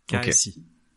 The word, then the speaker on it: xi: C'est la lettre xi. Kasi. – Kasi. Ok. – Enfin, ça se prononce kasi.